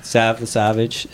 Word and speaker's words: Savv the Savvge: Sav the Savage.